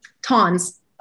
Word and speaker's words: Tonz: Tonz.